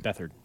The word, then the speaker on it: Beathard: Bettered is that how you say his name? The their backup. Beathard. CJ Beathard. Beathard.